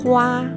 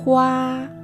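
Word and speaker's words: hua (花): Y en chino se dice hua, hua, hua.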